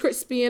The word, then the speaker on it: Chrispy: And my name is Crispy, and that's spelled C-H-R-I-S-P-Y, also known as Lando Calrissian, also known as I'm P-E-T-T-Y, also known as, you heard me, huh? – I'm black again.